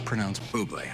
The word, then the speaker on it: Bublé: It's pronounced Bublé. I know, thanks. – Can I have your autograph, Mr. Bubbly? – I love it. – It's Bublé. Bublé.